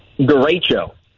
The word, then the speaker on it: Derecho: Derecho.